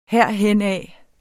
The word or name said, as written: herhenad